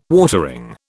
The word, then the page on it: watering: British English